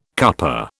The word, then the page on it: cuppa: British English